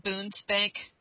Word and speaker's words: Bundesbank: Now, you also mentioned Bundesbank. Is that how you pronounce it? Bundesbank? Bundesbank? – Bundesbank, yes.